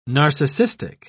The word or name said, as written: narcissistic